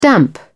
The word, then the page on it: damp: British English